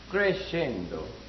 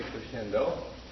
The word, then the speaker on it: crescendo: That's pronounced in Italian, crescendo. So we say crescendo. And it's abbreviated music, C-R-E-S-C, period.